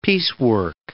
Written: piecework

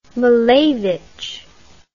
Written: malevich

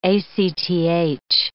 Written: ACTH

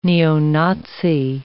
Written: neo-Nazi

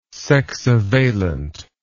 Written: sexivalent

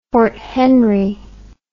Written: fort henry

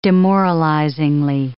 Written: demoralizingly

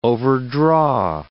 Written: overdraw

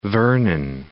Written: Mount Vernon

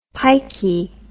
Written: pikey

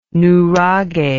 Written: nuraghe

